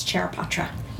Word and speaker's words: Charaipotra: And Sona, do you mind um, pronouncing your last name for me? It's Charaipotra.